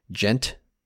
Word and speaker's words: Djent: Djent, or, I think that's how you pronounce it. It's D-J-E-N-T.